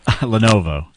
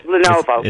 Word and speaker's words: Lenovo: Levelo? – Lenovo. – Oh, right, yeah, Lenovo.